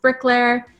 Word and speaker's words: Bricleir: It's pronounced Bricklayer, and it's basically Bricklayer in Old Irish.